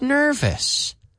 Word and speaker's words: nervous: But today I want to practice the word nervous because it rhymes with Elvis. Nervous. Vis. We don't say nervous. Nervous. Oh, even though it ends in O-U-S, we say is. Nervous. Vis. Or vis. Casi. It's not exactly the same, but almost. If you think of it, if you pronounce it like Elvis, nervous, it's not quite perfect, but it's much better than the Spanish style.